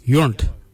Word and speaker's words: Yoornd: Yourned. Yourned is spelled Y-O-O-R-N-D.